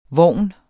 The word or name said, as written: vogn